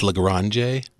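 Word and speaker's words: La Grange: however you want to pronounce it. – It's LaGrange.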